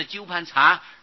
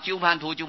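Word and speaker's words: 鸠槃荼: 那么现在经文里面都印作土字，不是查字啊，但是这里应当是纠盘查而不是土啊，念作纠盘查归啊，因为这是印度话啊，原来是纠盘查新译，有些译作查是那个巡查的查啊，查看的查，那么那就不会念成土字了。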